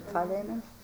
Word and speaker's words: Philemon: Philemon.